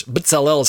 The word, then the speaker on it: Bezalel: Bezalel is how I say it in Hebrew.